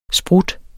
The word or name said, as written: sprut